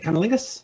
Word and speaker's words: cunnilingus: How do you say it? Cunnilingus? – Cunnilingus. – Cunning linguist. Okay.